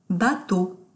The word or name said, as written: bateau